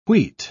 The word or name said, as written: wheat